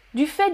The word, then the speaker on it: du fait: Alors, ah oui, Mariam, question, on prononce la lettre T ici, oui, du fait, oh, du fait de, du fait de, on la prononce pas, en fait, on prononce. Oui, en fait, tu prononces le T.